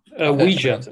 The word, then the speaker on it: Uyghur: given the issues with the Uyghurs. Is that how you say it? – Uyghur, yeah. – Uyghur. There we go. Yeah. I've always been pronouncing that wrong in my head.